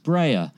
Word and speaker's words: Brea: Also, Brea Grant's name is pronounced Brea, and 12 Hour Shift is not Brea's directorial debut.